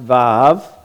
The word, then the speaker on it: Vav: The Vav, right there, which you have here in verse 41, it says Wa, that's pronounced Vav, that's the Vav,